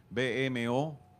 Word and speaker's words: BMO: se pronuncia b m BMO.